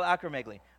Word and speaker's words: acromegaly: And seriously, and it's called, I'm going to say this wrong, acromegaly. What is it? I said it right? Thank you. It's called acromegaly.